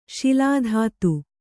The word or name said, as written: ಶಿಲಾಧಾತು